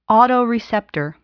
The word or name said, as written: autoreceptor